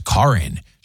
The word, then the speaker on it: Karyn: Karen and Kevin, I worked with a girl of Latino descent, and her name is spelled Karen, K-A-R-Y-N. Uh-huh. However, she came in saying to pronounce it as Karin. She did this on purpose because of all the lore that follows Karens around these days. Mm. And it's correctly pronounced that way in Spanish, she said.